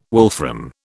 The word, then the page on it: wolfram: British English